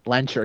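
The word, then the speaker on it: Lehnsherr: And it would make sense that it's Magneto, considering obviously the comics tie-in that Wanda Maximoff has Eric Lesher. – Lencher, excuse me, Zanger. I'm sorry for putting the emphasis on the wrong part of the name.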